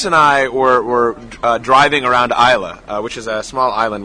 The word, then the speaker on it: Islay: Well, it's spelled, I-S-L-A-Y, but we got to notice that they pronounce it Isla.